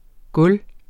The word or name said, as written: gulv